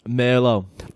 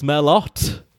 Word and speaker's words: Merlot: How would you pronounce a famous red wine? – Merlot. – Incorrect. – Oh! – Yes. Actually, it's Merlot.